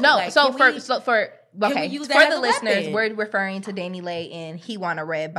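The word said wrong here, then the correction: DaniLeigh: Danny Lee, bitch. – Danny Lee, Danny. Okay, sis. I don't like silent G's and H's and shit.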